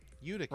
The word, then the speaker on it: Utica: Is it Utica? – Utica. – Right? Yeah, Utica.